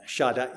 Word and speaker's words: Shaddai: Shaddai, if you want to pronounce it in Hebrew, but it is where we speak English.